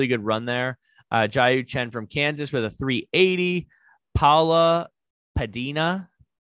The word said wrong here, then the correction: Pineda: Pineda. Looks like, yes, Pineda. Yes, thank you. I knew I was saying it wrong. Pineda, you got it.